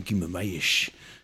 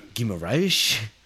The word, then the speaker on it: Guimarães: Guimarães. – Who? – Guimarães. – Oh, Bruno Guimarães, yeah.